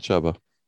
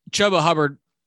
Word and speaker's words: Chuba Hubbard: Chuba. – Chuba Hubbard, 23 yards and a touchdown.